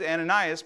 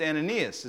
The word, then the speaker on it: Ananias: and I just this week discovered I've been saying that name wrong. I, I know, I was shocked. I thought it was Ananias, but if you look at it on the screens, it's Ananias.